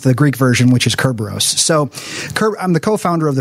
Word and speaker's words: Kerberos: named Kerberos, or I think that's the Greek pronunciation. Latin is Cerberus.